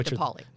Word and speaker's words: DePauli: I always just say DePauli. – DePauli. Allison DePauli, even though it's two words and it doesn't quite look like it sounds.